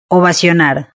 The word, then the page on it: ovacionar: Spain Spanish